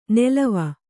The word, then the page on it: ನೆಲವ: nelava